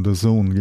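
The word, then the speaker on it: DAZN: Du kannst zum Beispiel bei, wie spricht man es richtig aus? – The Zone, genau, das letzte Mal schon.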